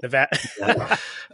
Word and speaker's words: Nevada: Nevada.